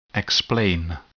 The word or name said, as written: explain